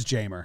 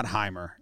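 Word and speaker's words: Jeimer: I've been saying Jamer. – It is Jamer. – It is Jamer. I like Heimer. – It's not Heimer. It's Jamer. I heard the Tigers announcers say that, and I double-checked it.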